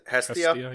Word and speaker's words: Hestia: Hestia, yep.